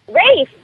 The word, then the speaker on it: Ralph: Rafe. That sounds great. – And it's spelled R-A-L-P-H.